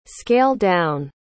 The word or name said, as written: scale-down